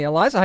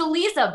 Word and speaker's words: Aliza: Aliza, how you doing? – Good. It's Aliza, by the way. – It is Aliza.